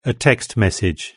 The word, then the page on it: a text: British English